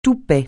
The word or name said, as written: toupet